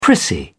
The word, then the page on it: prissy: British English